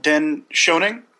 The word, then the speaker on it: Dan Schoening: Now, you mentioned Dan Schoening. Am I pronouncing it correctly? – Oh, yeah.